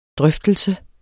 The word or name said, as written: drøftelse